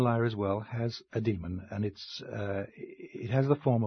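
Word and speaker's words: dæmon: Yeah, I pronounce it demon, but it's spelled D-A-E with a ligature, M-O-N.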